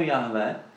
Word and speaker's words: Jahve: takže nejspíš je to Jahve, rozhodně to není Jehova, jenom abyste to viděli.